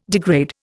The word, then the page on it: degrade: Indian